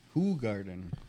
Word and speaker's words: Hoegaarden: Hoegaarden.